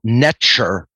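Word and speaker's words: nṯr: And that would be breaking down the consonants NTR, which in ancient Egyptian, NTR pronounced netcher, which is very, very similar to the English word nature, just a different vowel sound in between the N and the T.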